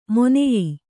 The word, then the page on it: ಮೊನೆಯೀ: moneyī